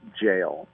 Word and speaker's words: gaol: It looks like Gaol, G-A-O-L, but they pronounce it jail.